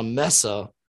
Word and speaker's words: mesa: 2009, a woman out for a walk with a dog discovered what she believed to be human bones on a mesa near Albuquerque. What's a mesa? – Mesa is how you pronounce that. Mesa.